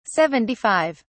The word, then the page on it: 75: British English